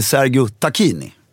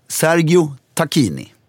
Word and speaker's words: Sergio Tacchini: Vad sa du? – Sergio Tacchini. – Nej. – Jo. Korrekta uttalet, jag har fått nu höra det av VD:n för Triva. Jag har själv googlat det och kollat på YouTube.